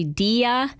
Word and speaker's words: Dige: And I really butchered the pronunciation of her name because it's spelled D-I-G-E. But now I know it's Dia.